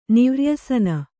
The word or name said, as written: ನಿವ್ರ್ಯಸನ